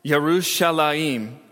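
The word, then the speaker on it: Yerushalayim (ירושלים): The place named Jerusalem, pronounced Yerushalayim in Hebrew, is a combination of two words.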